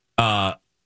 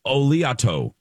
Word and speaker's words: Oleato: Platinum Panda has given us the pronouncer. – Oh, good. – Oleato. – Oleato. – Oleato. – Oleato. – Oleato. Yeah, it's very Italian.